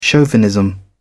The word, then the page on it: chauvinism: British English